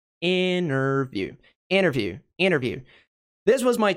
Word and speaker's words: interview: Então, a nossa sílaba tônica aqui é interview, interview, interview, interview. Tá? Interview, interview, interview. Tá? Não é ter.